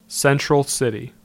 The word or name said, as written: Central City